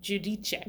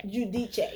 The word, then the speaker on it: Giudice: How do you pronounce it? – Giudice. – Giudice, yes.